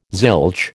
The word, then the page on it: zilch: American English